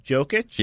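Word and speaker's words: Jokić: His name rhymes with Shmokic. – Jim Jokic? – Yeah.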